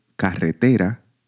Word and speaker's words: carretera: In the word carretera, for example, the stressed, the strongest intensity with which we pronounce a syllable within a word, falls on the penultimate syllable, te.